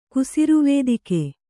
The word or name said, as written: ಕುಸಿರುವೇದಿಕೆ